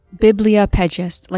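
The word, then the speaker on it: bibliophagist: Bibliopagist, like bibliography. – Bibliopagist, okay. – Well, he's right. It's not like the Spanish, like biblioteca. That would be the biblio.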